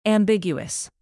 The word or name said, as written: ambiguous